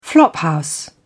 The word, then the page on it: flophouse: British English